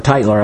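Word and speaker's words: Tytler: or Titler, I guess is how it's pronounced, attributed to him, Perils of Democracy, written in the 18th century.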